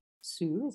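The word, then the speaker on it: Hsu: played by the extraordinary Stephanie Sue. Is that how you pronounce? – Yeah, yeah, of course.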